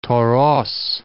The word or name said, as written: Toros